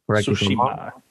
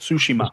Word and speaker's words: Tsushima: They have Doom Eternal, Final Fantasy VII Remake, Ghost of Tsushima, if I'm saying that correctly. – Tsushima. Yeah. – Tsushima. – Like Hades. Sushi. Like Tsushima.